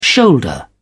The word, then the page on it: shoulder: British English